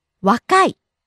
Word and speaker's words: わかい: わかい。